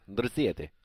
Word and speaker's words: Dorsett: Philip Dorsett, I believe is how it's pronounced. Cool. – No, Dorsett. – That's right.